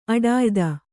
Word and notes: ಅಡಿಯೊತ್ತು: aḍiyottu; Kannada

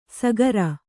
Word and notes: ಸಗರ: sagara

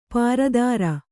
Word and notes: ಪರಿದರ: Kannada

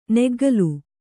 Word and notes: ನಗ್ಗಲು: kannada

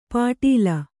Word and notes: ಪಾಟೀಲ: pāṭīla

